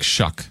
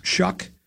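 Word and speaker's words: Shough: I think it's like Shuck. – Shuck.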